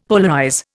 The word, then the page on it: polarize: Indian